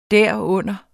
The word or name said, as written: derunder